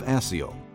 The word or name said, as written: Asiel